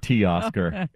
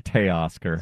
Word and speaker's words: Teoscar: How dare you not know anything about Teoscar Hernandez? What is the matter with you? God. – Well, watch Blue Jays baseball, would you? – First of all, it's Teoscar, not T-Oscar. It's Teoscar.